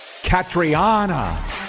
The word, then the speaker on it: Catriona: Both Lee and I were wrong. Catriona. We got it.